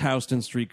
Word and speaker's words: Houston Street: I appreciate Alec Baldwin, a New Yorker, bothering to pronounce Houston Street correctly.